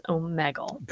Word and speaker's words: Omegle: I'm pretty sure that's how you say it. I Googled it because I've heard people say Omegle. And according to Google, it is Omegle.